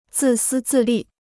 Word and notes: 自私自利: zì sī zì lì

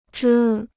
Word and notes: 遮: zhē

